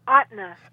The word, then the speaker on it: Ahtna: How do you how do you pronounce that? – Ahtna. Ahtna. Ahtna.